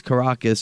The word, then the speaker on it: Caracas: And we heard him say Caracas. We know it's Caracas, so we apologize for the mispronunciation there.